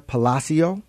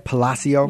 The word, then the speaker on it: Palacio: Today's guest is Jessica Palacio, Director of Marketing with UMOM New Day Centers.